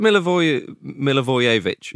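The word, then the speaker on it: Milivojević: Is it Milivojevic? – Yeah.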